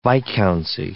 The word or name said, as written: viscountcy